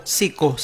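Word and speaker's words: Psycho: El grupo Sico se escribe P-S-Y-H-C-H-O, Sico se pronuncia.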